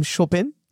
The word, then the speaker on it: Chopin: First thing first, I think the Poles really hate it when you have the French pronunciation of Chopin. – Yeah. – So the Poles would call him Chopin.